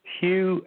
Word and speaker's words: Hugh: Yeah, yes, my name is Hugh, H-U-G-H. The last name, Trawlson, is spelled T like Tom, R-A-U-L, S like Sam, E like Edward, N like Nancy.